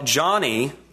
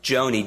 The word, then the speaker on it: Joni: Well, Johnny, and her name is pronounced that way, I thought it was Joni. Joni would be the normal pronunciation of that, but her father's name was John, and she was named after her father, so J-O-N-I is Johnny.